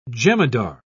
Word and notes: jemadar: British English

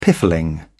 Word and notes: piffling: British English

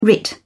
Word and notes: writ: British English

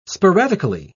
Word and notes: sporadically: British English

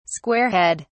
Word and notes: squarehead: British English

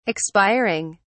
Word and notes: expiring: British English

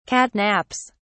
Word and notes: catnaps: British English

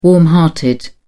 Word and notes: warm hearted: British English

